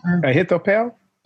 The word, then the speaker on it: Ahithophel: Ahithophel.